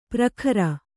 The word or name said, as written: ಪ್ರಖರ